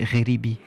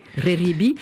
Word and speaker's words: Xeribi: Vous avez repris alors le titre qu'on va écouter c'est, alors ça s'écrit X-E-R-I-B-I, comment vous prononcez ? – Oui, et on dit Reribi. – Reribi, c'est un titre que vous avez chanté vous aussi, mais vous avez remplacé le terme Kurdistan par Utopia.